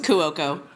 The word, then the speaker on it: Cuoco: I've never heard it Cuoco, though. – It's Cuoco.